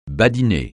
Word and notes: badiner: francais

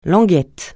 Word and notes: languette: francais

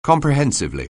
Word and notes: comprehensively: anglais

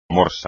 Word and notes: morsa: Spanish

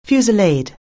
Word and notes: fusillade: anglais

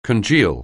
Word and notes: congeal: anglais

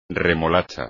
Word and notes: remolacha: espagnol

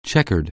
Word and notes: checkered: anglais

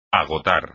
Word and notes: agotar: espagnol

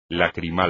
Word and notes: lacrimal: Spanish

